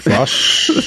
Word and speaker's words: Fotsch: Fotsch.